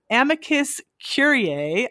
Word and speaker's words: amicus curiae: who lends his time as an amicus curiae, I think I'm saying that right, for all those of us, did I get it right? – Yeah.